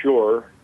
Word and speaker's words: Schuur: A man named David Shure, his name is spelled S-C-H-U-U-R, and if one Googles UFO David Shure, you can find his on-the-record testimony now online.